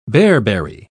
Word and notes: bearberry: British English

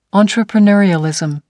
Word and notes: entrepreneurialism: American English

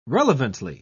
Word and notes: relevantly: British English

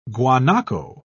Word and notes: guanaco: British English

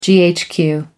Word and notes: GHQ: American English

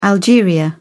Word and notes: algeria: British English

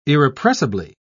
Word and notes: irrepressibly: British English

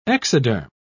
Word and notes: exoderm: British English

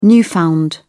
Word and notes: newfound: British English

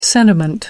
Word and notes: sentiment: American English

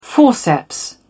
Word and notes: forceps: British English

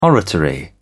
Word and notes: oratory: British English

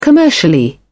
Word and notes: commercially: British English

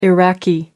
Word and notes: iraqi: American English

